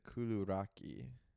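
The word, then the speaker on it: koulourakia: Yeah, this is a Kaludia, Kaludi. That's what we grew up calling them, although on the back it says you're supposed to pronounce it Kuluraki.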